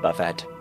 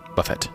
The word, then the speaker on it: buffet: I pronounce the T in buffet. – Buffet. That's buffet. Warren Buffett.